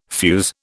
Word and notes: fuze: British English

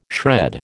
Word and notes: shred: American English

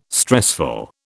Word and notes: stressful: British English

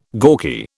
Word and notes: gawky: British English

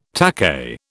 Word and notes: takahe: British English

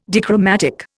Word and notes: dichromatic: Indian

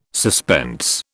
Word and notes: suspense: British English

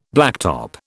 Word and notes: blacktop: British English

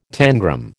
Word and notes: tangram: American English

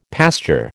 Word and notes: pasture: American English

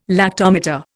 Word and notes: lactometer: Indian

